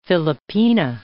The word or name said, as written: Filipina